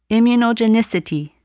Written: immunogenicity

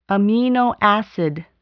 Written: amino acid

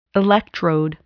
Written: electrode

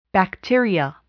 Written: bacteria

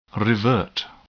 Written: revert

